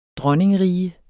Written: dronningerige

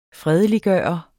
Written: fredeliggøre

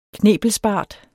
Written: knebelsbart

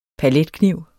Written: paletkniv

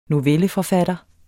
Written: novelleforfatter

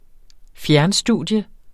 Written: fjernstudium

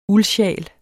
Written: uldsjal

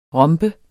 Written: ROMBERNES

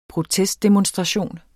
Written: protestdemonstration

